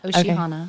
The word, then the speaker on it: Oshihana: So tell us about, am I saying this right? Oshihana? – Yep, Oshihana.